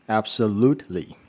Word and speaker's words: absolutely: 好，第一个要跟各位介绍的字就是，当我们完全同意可以回答 Absolutely。 Absolutely，那这个字的发音各位可以注意一下，就是念很标准的话，absolutely 那个 t，很多时候会有些人会念得太用力，因为很认真想要念好，所以会念得太用力。